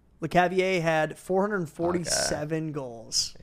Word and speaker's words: Lecavalier: Jamie Benn or Vincent LeCavalier? Is that how you say it? – Yeah, LeCavalier. – LeCavalier? – Yeah.